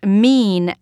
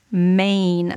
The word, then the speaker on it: mien: One of them, of the many that I didn't know how to pronounce, was mean, M-I-E-N, as in mild mean, somebody's face. And I thought it was pronounced mane, like a lion has a mane.